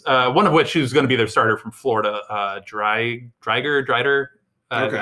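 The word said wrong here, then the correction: Driedger: I don't know how exactly pronouncing it, but Drieger. – Drieger, yes.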